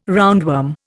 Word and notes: roundworm: Indian

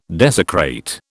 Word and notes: desecrate: British English

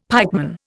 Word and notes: pikeman: Indian

